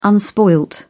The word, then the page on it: unspoiled: British English